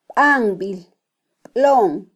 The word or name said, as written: banbilblon